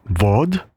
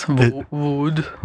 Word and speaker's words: Vaud: So I've been calling it Vaud. It's Vaud. – Well, the French pronunciation, I guess. Vaud.